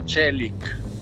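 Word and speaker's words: Çelik: Si pronuncia Celic, no Celic, Celic, che significa acciaio.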